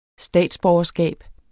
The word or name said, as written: statsborgerskab